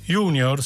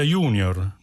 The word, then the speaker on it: junior: Perciò plus si pronuncia plus, non plus. Media si dice media, non media. Junior si pronuncia junior, non junior. Se il fidanzato insiste affinché diciate junior, non cambiate pronuncia, cambiate fidanzato.